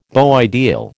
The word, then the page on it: beau ideal: American English